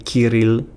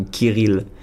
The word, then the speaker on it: Kyril: Même si je suis né en France, qui est donc dit prononcé Kirill ou Kirill. – D'accord. – Donc K-Y-R-I-L.